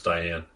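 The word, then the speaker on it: Dian: And story of Dion Fossey. – Yeah. Diane. It's pronounced Diane.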